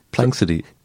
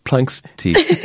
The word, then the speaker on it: Planxty: It's Planxty. – Sorry? – That's right. – Planxty.